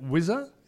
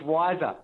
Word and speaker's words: Wisr: Now, but also even the business you work for, is it Whizzer? Is that the right pronunciation? W I S R. It's Wiser.